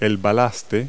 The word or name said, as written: ballast